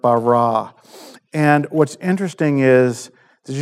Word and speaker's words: bara: The Hebrew word for the word created is bara. Okay, I don't know if you guys that took Hebrew or if I'm saying that right, but it's like it's B-A-R-A, but like the pronunciation is like B-A-W-R-A-W, bara.